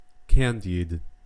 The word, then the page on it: candied: American English